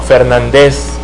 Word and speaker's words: Fernández: Se había dado los soviéticos con gol de Vasily Rats, mientras que el buen Luis Fernández, o Fernández, si es que queremos pronunciar en franchote.